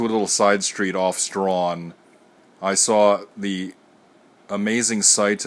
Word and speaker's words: Strachan: I had to walk up Strawn, which looks like it's pronounced Strachan, but it's pronounced Strawn,